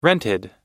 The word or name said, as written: rented